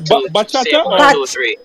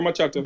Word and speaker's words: bachata: What does it pronounce? Bachata? – Yeah, bachata. B-A-C-H-A-T-A. – Okay, yeah, so maybe that's the pronunciation, but it's like a 1-2-3 swing type of thing. – Okay, yeah. – And it says bachata, right? Or machata? – Bachata, yes.